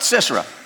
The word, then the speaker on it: Sisera: Sisera? How do you say it? Sisera? Sisera. I'd call him Cicero if I know, but Sisera.